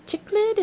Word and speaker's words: cichlid: And he's a cichlid. Am I saying that correctly? – That sounds right. Yes.